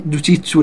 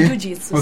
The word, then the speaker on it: Jiu-Jitsu: e sei atleta di Jiu-Jitsu, ho detto bene? – Jiu-Jitsu, sì.